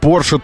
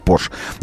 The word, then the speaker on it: Porsche: Не об этом же был комментарий вообще, но тем не менее вызвал какую-то дискуссию, дескать, что правильно говорить Porsche. Нет, неправильно говорить Porsche. Запомните и зарубите это у себя где-нибудь. Porsche — это фамилия. Его звали Фердинанд, а имя его, а фамилия его иная — Porsche.